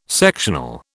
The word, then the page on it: sectional: British English